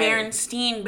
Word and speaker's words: Berenstain: Berenstain Bears. And you don't or even the, say it like Stain.